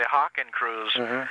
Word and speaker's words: Hakenkreuz: Phonetically, you might say Hakenkreuz, which is basically H-A-K-E-N-K-R-E-U-Z.